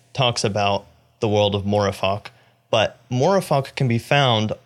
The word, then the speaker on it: Mooreeffoc: But Chesterton talks about the idea of Morefok, which is spelled M-O-O-R-E-E-F-F-O-C.